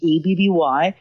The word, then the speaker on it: Abby: And my name is spelled ABBY. That is because sometimes people put an E or an I or an IE, but it's just ABBY.